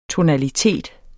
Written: tonalitet

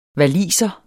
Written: waliser

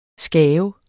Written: skaves

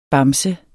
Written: bamse

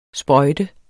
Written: sprøjte

